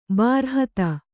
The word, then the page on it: ಬೃಹತ್ತು: břhattu